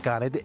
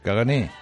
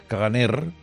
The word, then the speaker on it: caganer: Lleva R, cagané, pero no se pronuncia. – ¿Y entonces para qué la ponen? – Bueno, pues pasa con muchos idiomas. Hay muchas letras que van, pero no se pronuncian. No es en todo caso sería caganer, vale. Pero la R no se pronuncia, es un cagané.